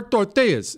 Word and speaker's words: tortillas: Flour tortillas. And first of all, say it right. What, Chris? Why do you make that face? – Buenas tardes. – Tortillas is how you say it.